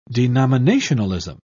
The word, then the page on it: denominationalism: British English